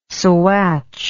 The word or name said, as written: saguache